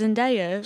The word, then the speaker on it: Zendaya: It's Zendaya.